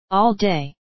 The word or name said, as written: all day